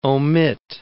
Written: omit

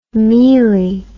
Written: mielie

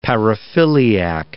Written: paraphiliac

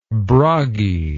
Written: Bragi

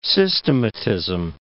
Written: systematism

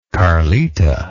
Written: carlita

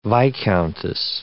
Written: viscountess

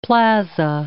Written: plaza

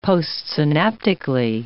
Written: postsynaptically

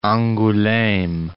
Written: Angouleme

